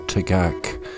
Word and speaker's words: Tagak: My next track is from the artist and writer and throat singer Tanya Tagak. It's called Eerie Changes, and I have got that pronunciation right.